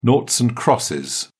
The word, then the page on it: noughts and crosses: British English